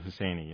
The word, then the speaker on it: Husseini: Is that how you pronounce his name? – I pronounce it Husseini.